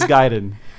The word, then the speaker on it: Gaiden: That was if you guys needed an extra hint, I was going to say this game is also often, the title is mispronounced a lot. – Oh, well. – It is Gaiden.